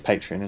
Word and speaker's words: Patreon: Is that how you say it? It is Patreon, isn't it? – Patreon, yeah, I suppose. Patreon. Yeah, yeah.